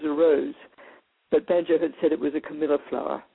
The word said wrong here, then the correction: Camellia: And is it a Camilla or a Camellia? I don't know if I say that wrong. Is it a Camilla? – The flower is a Camellia.